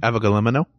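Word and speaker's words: avgolemono: We have avolemono. How do you say that again? – Avogolemono.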